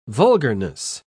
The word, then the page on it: vulgarness: British English